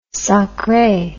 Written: sacre

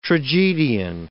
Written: tragedian

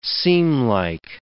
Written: seamlike

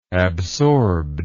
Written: absorbed

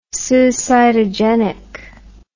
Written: suicidogenic

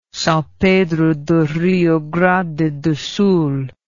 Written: Sao Pedro do Rio Grande do Sul

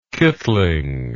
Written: kitling

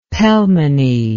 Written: pelmeny

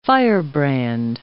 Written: firebrand

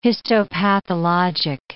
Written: histopathologic

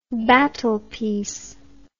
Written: battle piece

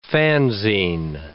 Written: fanzine

